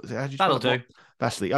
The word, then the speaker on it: Szoboszlai: Dominic Szoboszlai, what was it? Szoboszlai. Okay.